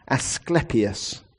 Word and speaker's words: Asclepius: It is Asclepius, the god Asclepius.